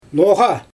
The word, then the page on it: noGa: Xyzyl